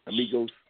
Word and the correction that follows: Migos: Migos. – Migos.